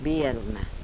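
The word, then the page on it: berma: dialetto croato di Lukezi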